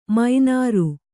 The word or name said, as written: ಮೀನಾರ್